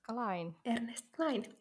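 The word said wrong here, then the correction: Cline: tai Kline, Kline. tälleen niin kuin me lausutaan ihmisten nimet, niin se on Ernesti Kline.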